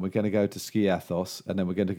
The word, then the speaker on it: Skiathos: I'm going to Skiopos. I never pronounce it. – Skiopos. – I've been. I don't know whether you've used the weather app on iPhones. – No. – Skiathos. – Oh, Skiathos.